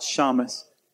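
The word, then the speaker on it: shamash: How do you pronounce that? – Shamus. – Okay. And the Shamus...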